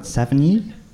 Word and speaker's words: Savigny: Say that right, Savigny? – If no one's correcting me, I'll... Yeah. Happy with that? – Yeah.